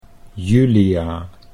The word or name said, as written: Julia